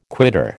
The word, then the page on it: quitter: American English